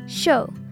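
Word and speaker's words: chaud: Show. Like you're saying, I'm going to watch a show.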